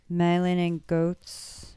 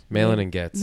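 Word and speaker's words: Malin+Goetz: How do you say it? Melon and goats. – Malin and Goetz. Melon and gets.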